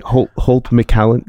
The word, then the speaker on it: McCallany: Holt McCallany.